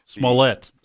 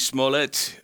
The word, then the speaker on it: Smollett: Like an omelet. – Smollett omelet. Well, Jussie Smollett, the chief of police of Chicago, says that they have a slew of evidence that shows he's making it up.